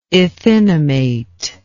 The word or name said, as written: ethinamate